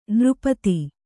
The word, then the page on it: ನಿರುಪಾಧಿ: nirupādhi